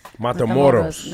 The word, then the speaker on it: Matamoros: Matamoros. Matamoros.